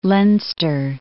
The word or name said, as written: Leinster